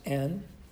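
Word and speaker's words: En Eglaim: Also, Eneglaim, it's pronounced Eneglaim in Hebrew, is another village And it, in fact, adds up to 153 in Hebrew, using the principles of Hebrew gematria.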